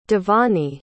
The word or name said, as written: Devanie